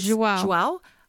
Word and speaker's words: João: How do you say this? João Martins? – João.